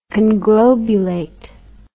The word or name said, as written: conglobulation